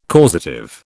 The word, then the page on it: causative: British English